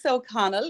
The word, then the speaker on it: O'Connell: And Denise O'Connell is my name.